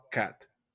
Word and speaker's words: quatre: Because it's spelled like quarter spelled wrong is what it looks like. It's like Q-U-A-T-R-E or something like that. – Yes. – I would pronounce like this. Un, deux, trois, quatre. – Quatre. – Quatre. – Yeah, that's how you say it.